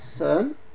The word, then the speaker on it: Son: In Korean it's called Sun, S-O-N, and in Vietnamese it's called Tien, T-H-I-E-N.